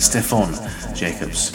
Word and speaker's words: Stephen Jacobs: Actually, it's pronounced Stefan Jacobs.